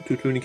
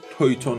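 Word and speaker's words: Teuton: توتونی که توی دخانیات هست از تلفظ آلمانیش یعنی تویتون ها استفاده می کنم توتون تلفظ انگلیسی اسم این قومه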